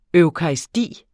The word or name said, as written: eukaristi